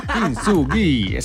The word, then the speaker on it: Kintsugi: Kintsugi.